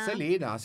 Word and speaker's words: Selena: Voi lo sapete ormai che noi la chiamiamo Selena. – Selena. No, ma è proprio la pronuncia corretta del suo nome.